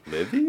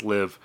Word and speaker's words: Liv.e: or L-I-V period E. – Livy? Maybe? I don't know. – The E is silent. – Ah, got it. – I looked this up to make sure. Anyway, L-I-V period E, pronounced Live, who put out one of the most acclaimed albums of early 2023.